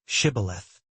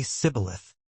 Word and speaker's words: Shibboleth: "Say the word, Shibboleth." But the Ephraimites couldn't pronounce it right. They'd say Sibboleth.